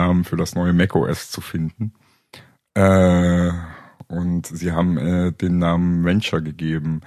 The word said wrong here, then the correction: Ventura: Einspruch. Ventura. Spricht man das Venture aus? Nein. – Nee, ist eine gute Frage. Ventura, ja. Ich glaube, Ventura. Ich glaube, du hast recht, Carsten. Das heißt Ventura.